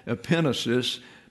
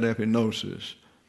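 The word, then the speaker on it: epignosis: This is divine thought, and that's called epenesis, but we pronounce that epinosis.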